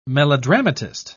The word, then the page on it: melodramatist: British English